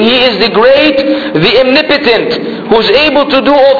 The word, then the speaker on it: omnipotent: Omnipotent and omniscient. Omnipotent. But the way it's pronounced is omnipotent, unfortunately.